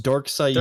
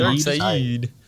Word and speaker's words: Darkseid: It's pronounced Darkseid. – Darkseid.